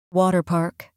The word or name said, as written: water park